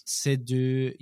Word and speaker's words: Seydoux: That's why I suggested we should talk about it on this pod, because you've got Léa Seydoux. Is that how you pronounce her name? – Yes, Seydoux.